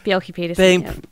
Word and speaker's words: Bjelke Petersen: Yeah, Bjelke Petersen.